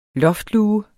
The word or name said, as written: loftluge